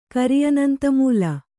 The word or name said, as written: ಕರಿಅನಂತಮೂಲ